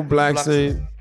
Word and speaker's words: Blackson: And I am Michael Blackson. Like Jackson.